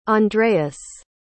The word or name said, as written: andreas